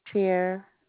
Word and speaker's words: Tira: Tier. – I'm sorry? – Tier. – Tier? – Yes. – Could you spell it for me? – T-I-R-A. – T-I-R-A.